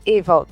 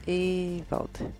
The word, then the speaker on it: Ewald: Como se pronuncia seu sobrenome? – Aline Ewald. Ewald. – Ah, esse de. Evald. É, tem gente que me chama de Aline Evaldo.